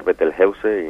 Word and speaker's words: Betelgeuse: Betelgeuse, no sé cómo se pronuncia esto, va a explotar. ¿Qué, sí. ¿Qué es esto? ¿Qué es esta noticia? – Bueno, la podemos pronunciar Betelgeuse y no pasa nada. En español no hace falta que lo pronunciemos de formas raras, ¿no?